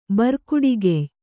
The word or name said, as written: ಬರ್ಕುಡಿಗೆಯ್